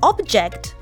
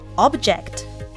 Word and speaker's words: object: object」という発音になりますスペルは同じだけど発音は違いますものだと「 object」反対するだと「 object」ここに要注意です